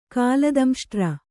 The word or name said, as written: ಕಾಲದಂಷ್ಟ್ರ